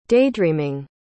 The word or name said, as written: daydreaming